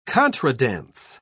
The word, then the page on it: contredanse: British English